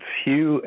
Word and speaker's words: Hugh: Yes, if you just put my name, it's Hugh, H-U-G-H, the last name, Charlson, is spelled T like Tom, R-A-U-L-S-E-N like Nancy, after my name.